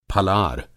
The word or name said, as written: फलार